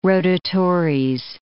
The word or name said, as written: rotatores